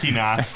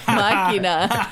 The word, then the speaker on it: Machina: The Right Game is talking about Ex Machinima and BB-8. Because he can't stop. – Machina. – Machina. Oh, sorry.